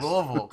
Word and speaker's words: Louisville: Louisville, Kentucky, not Louisville. Louisville. Those right. Northerners. – Exactly. It's we like to say it's 2 1/4 syllables. It's Louisville with a in the middle.